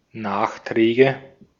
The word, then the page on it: Nachträge: /naːχˌtʀɛːɡə/